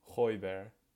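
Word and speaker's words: Højbjerg: Say Holzberg. Is that not how you say it? How do you say it? Hoiberg.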